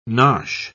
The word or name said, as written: nosh